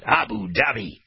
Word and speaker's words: Abu Dhabi: It's just a name, the way you pronounce it, Abu Dhabi, right? Everybody say it together. Say Abu Dhabi. – Abu Dhabi.